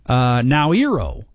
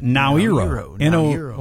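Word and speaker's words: Naoero: President David Adang announced the change, which aligns with the local pronunciation Nauru. That's how you pronounce it. Nauru. Like N-O-W and then E-R-O, Nauru.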